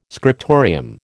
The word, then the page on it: scriptorium: American English